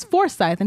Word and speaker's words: Forsyth: And he was like, how do you pronounce it? Forsyth? And she was like, it's Forsyth. And he was like, okay.